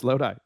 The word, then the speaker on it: Lodi: So the McCollum Hatchery is by Lodi. Do I pronounce that right? – Yes, Lodi.